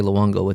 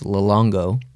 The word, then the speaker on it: Luongo: Because Luongo, he can't say Luongo, it's Luongo.